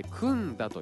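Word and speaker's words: 栗田: 栗という字に田んぼの田と書いて、くんだと読むそうですが、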